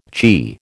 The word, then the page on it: chi: American English